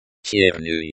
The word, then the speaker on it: черный: Sound in Russian is pronounced similar to the, ch, in the English word, chair. English, Russian black chair, черенерликаткое. – Черный.